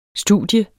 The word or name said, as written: studie